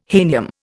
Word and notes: hahnium: Indian

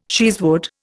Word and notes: cheesewood: Indian

